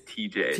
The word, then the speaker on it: TJ: Also, I realized you're saying EJ, it's TJ.